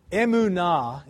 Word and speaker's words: emunah: Emunah is the word, emunah.